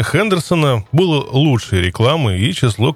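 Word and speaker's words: Henderson: Кстати сказать, некоторое количество таких мотоциклов в свое время попало в Россию, но по известному нашему пренебрежению к иностранным словам марка произносилась как Гендерсон.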